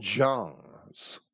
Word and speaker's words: Jung: Checking the American Heritage College Dictionary for the pronunciation of the Swiss psychiatrist Carl Jung's last name, we find Jung, Y-O-O-N-G, and the Swiss city Jungfrau is Jungfrau.